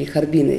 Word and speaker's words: Харбин: Как раньше говорили, Харбина, если говорить правильно.